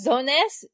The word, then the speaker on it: Zones: I've seen the katakana for it, and it's Zones. Z-O-N-E-S-U. Zones. it's in Romaji, or, you know, English cap, all capitals, Zones.